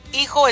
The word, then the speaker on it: hijo: Cuando ya me dijeron en el diccionario en español que cuando ve una H que no lo pronuncio, es como hijo y hija. Hijo es H-I-J-O, pero nomás dice hijo, no se pronuncia el H.